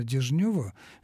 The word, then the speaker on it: Дежнев: По-моему, по поводу Семена Дежнева вы ошибаетесь. По-моему, он всегда и был Дежнев.